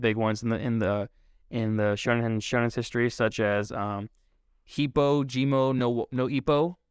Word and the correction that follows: Hajime no Ippo: the boxing anime. – Oh yeah, that one was huge. – Hajime no Ippo. – Yeah. – There we go. I butchered it the first time so hard.